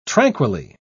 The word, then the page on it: tranquilly: British English